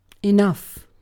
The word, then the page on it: enough: British English